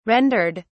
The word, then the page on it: rendered: British English